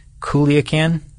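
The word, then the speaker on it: Culiacán: It's C-U-L-I-A-C-A-N. Culiacan, Mexico.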